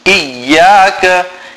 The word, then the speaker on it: إياك: A common mistake is to say إياك or إياك The correct way to pronounce it is إياك